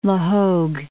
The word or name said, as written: La Hogue